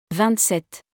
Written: 27

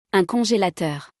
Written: Un congelateur

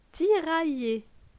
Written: Tiraille

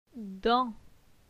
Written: Dan

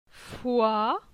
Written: Foi

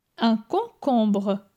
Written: Un concombre

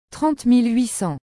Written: 30800

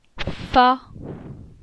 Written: Fa

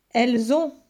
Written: Elles ont

